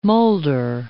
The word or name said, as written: molder